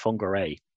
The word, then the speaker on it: Whangarei: Whangarei.